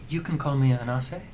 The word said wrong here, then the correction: Anansi: A-N-A-N-S-I? – Like Anansi. Like Anansi, sorry. – Like Anansi yeah.